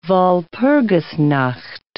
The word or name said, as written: Walpurgisnacht